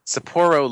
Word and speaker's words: Sapporo: One in Sapporo. One in Yokohama. Oh, I got that one right. One in Osaka. – Sapporo, like the beer.